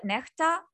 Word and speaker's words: Nexta: So they run this, it's called, well, they call it Nekhta, but it's spelled N-E-X-T-A, like Nexta. – Nexta, N-E-X-T-A.